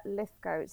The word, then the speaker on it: Lythgoe: So it's Clair Lythgoe, C L A I R, no E, L Y T H G O E.